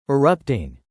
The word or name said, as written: erupting